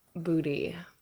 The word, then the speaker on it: Buti: So booty. – And it's B-U-T-I. – B-U-T-I. And it's actually pronounced with like a D. Booty.